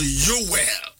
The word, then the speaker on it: Yoel: Hebrew for number pronounce Yoel. And I say Yoel.